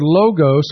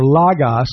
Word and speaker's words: logos: But the word, quote, Word, in this text, in the Greek is the word logos or logos, depending on how you desire to pronunciate it.